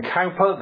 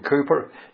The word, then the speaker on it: Cowper: William Cowper, the hymn writer, or William Cooper, depending on how you wish to pronounce his surname, suffered from melancholy.